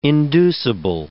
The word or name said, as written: inducible